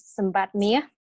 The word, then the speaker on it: Sambath: So my name is Sambath. That's how you pronounce it in Khmer.